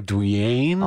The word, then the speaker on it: Dwyane: Yeah, I think Dwayne Wade is like the most high-profile case because it's spelled D-W-Y-A-N-E. It's like Dwayne.